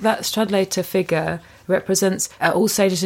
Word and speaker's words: Stradlater: Now, there's also a disgust at, is it Stradlater? I can never know how to say it. – Stradlater.